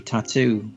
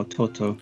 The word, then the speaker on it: Tautou: And it obviously brought into my life Audrey Tautou, which is, or Toto, as it's, I think, pronounced.